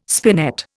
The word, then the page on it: spinet: Indian